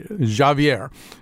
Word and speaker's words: Xavier: These young Guyanese characters, Adia plays Natalia, Sheyi Cole plays, I have to say it the right way, Javier. His name is spelled the way we would say Xavier.